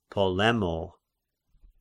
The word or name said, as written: Polemo